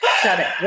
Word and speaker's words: Sade: Shade, where can people find you? – So I'm Shade Curry. Shade is spelled S-A-D-E, Curry, C-U-R-R-Y, like the food.